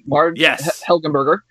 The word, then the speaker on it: Helgenberger: And Helgenberger. And Helgenberger. – They would go solve. Did I pronounce it wrong? – Yeah, no, you said it right.